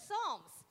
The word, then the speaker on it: Psalms: It starts with Psal- silent P. Silent P. yeah, silent P. – Yeah. And yeah, they're found in the book of Psalms.